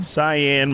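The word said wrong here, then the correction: Cillian: Murphy Lee. – Oh, Killian? Isn't it Killian?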